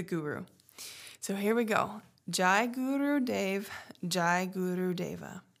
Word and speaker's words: Jai: And it's also pronounced, I've heard it pronounced J. So I just say, it sounds better saying Jai Guru Dev to me. Sometimes the J sounds better with other words, but Jai and J are, I believe, the same thing.